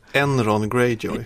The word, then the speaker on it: Euron Greyjoy: Jag vet inte om man uttalar det. Om man ska uttala det som man uttalar, alltså pengarna. – Euron Greyjoy. – Exakt.